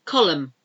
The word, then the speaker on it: column: Column, you don't say it there. A silent N in column. The column of letters or the column on a building. C-O-L-U-M-N.